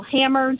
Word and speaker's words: Hammer: Hammer, just like the tool, and Crystal, just like the stripper.